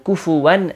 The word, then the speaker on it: كُفُوًا: Notice it's a kaf and not a qaf. A common pronunciation mistake is people say, وَلَمْ يَكُلْ لَهُ with a qaf, وَلَمْ يَكُلْ لَهُ وَلَمْ يَكُلْ لَهُ كُفُوًا أَحَدٍ. It's all kafs over there.